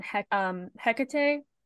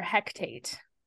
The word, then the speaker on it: Hekate: except for the fact that they call on Hekate or Hekate. I'm not sure which way is the right way to say it, but they say it wrong for sure. They call her Hectate, which is incorrect, and I was really annoyed by that.